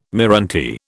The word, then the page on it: meranti: British English